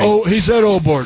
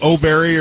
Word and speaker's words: Åberg: He said Åberg. – Åberg. Not O'Berry or something. – No, not O'Berry.